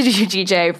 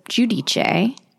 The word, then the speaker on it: Giudice: First of all, respect that you pronounce it Giudice, because some people like, do Giudice, which she says it's fine either way, but I just have an ounce more respect for people who say Giudice.